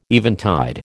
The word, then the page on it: eventide: American English